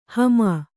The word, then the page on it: ಹಮಾ: hamā